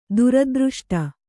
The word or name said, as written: ದುರದೃಷ್ಟ